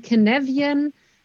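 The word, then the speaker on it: Cynefin: Also es ist fast richtig ausgesprochen. Es ist walisisch ähm, und heißt Kenevian-Modell. Und Kenevian bedeutet auf walisisch sowas wie Lebensraum.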